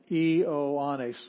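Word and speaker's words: Ioannes (Ἰωάννης): And Ioannes, you have to have a separate syllable for every vowel. You have three vowels stuck together there.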